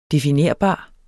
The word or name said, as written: definerbar